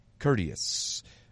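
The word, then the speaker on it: Kurdys: And your last name is pronounced how? – Courteous.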